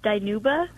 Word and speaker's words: Dinuba: It's actually pronounced Dinuba. – Dinuba? – It's okay. We're a really small town, just about 30 minutes outside of Fresno in the Central Valley. – Okay. Dinuba. Yeah. I apologize for not pronouncing it correctly.